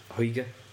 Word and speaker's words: hygge: Togetherness and social cohesiveness is also an underlying theme of Hygge.